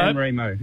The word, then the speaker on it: San Remo: How are we saying it properly? – Remo. – Like righto. – San Remo. – Yes, San Remo.